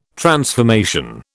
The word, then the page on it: transformation: British English